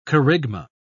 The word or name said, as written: kerygma